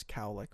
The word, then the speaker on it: cowlick: Yeah, that's like the origin of the name colic. Is cow? I'm pretty sure it is cowlick. We're saying it wrong, but whatever, yeah, dude.